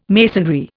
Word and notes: masonry: Indian

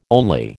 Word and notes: only: American English

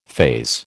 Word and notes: faze: American English

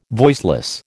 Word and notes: voiceless: American English

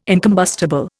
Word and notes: incombustible: Indian

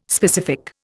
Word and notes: specific: Indian

